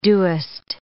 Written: doest

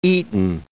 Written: eaten

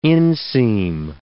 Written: inseam

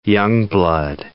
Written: youngblood

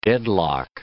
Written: deadlock

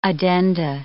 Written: addenda

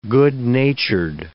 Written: good-natured

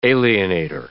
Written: alienator

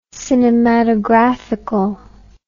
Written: cinematographical

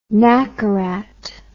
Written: nacarat

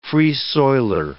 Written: Free-Soiler

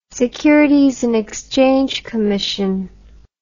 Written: Securities and Exchange Commission

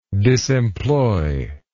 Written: disemploy

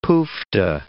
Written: pooftah

